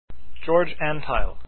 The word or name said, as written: antheil